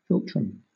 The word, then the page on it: philtrum: British English